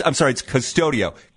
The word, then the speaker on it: Qustodio: I don't even know if I'm saying it right. It's Q-U-S-T and then studio. Q-U-S-T. No, it's Custodio. Q-U-S-T-O-D-I-O is what it's called.